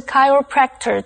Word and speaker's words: chiropractor: 最后我们看的是 chiropractor 这个名词。注意CH 在这里是发K的音, 念成 chiropractor。